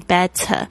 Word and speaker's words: better: 这个 better B-E-T-T-E-R 有人会念 better 或者是 better OK，因为呢这个是美式的发音的一个潜规则，如果呢 T 呢碰到夹在两个元音之间呢会有浊化的现象，好像是介于 T 跟 D 的声音，所以你要听得懂 better 那当然很多人会把这个 T 读得很脆，会念 better，这个也是没有关系的。